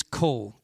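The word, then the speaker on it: kol (קול): The word voice in Hebrew, we switched it up a little bit, is kol, K-O-W-L. Pronounced kol.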